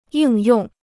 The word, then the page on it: 应用: yìng yòng